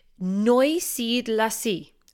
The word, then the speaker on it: Neusiedlersee: So after looking over several YouTube videos and pronunciation websites, I can now confirm what we should be saying later in the episode is Neusiedlersee, one of Europe's largest lakes situated in Austria, but it's also a DAC, Distritus Austriae Controllatus.